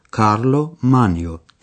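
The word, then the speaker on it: Carlo Magno: و اکس میدونه که ایتالیانا یعنی ایتالیایی ها نام قیصر رو کارلو مانیو تلفظ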